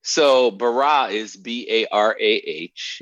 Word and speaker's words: bara: It's really spelled B-A-R-A. – Okay. – But people pronounce it Barah, and so we put the A-H on so they'd pronounce it right.